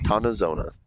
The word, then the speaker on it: Tontozona: Camp Tontozona. – Tontozona. That's how you say it.